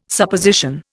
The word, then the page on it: Supposition: Indian